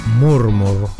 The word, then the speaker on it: Murmur: Marma, o sea, Murmur, así se escribe, aparecía en las bateas de, discos en Norteamérica y de allí hacia el resto del mundo.